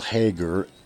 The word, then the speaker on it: Hager: You can actually go to Google and type in Paul Hager, and my last name is spelled H-A-G-E-R.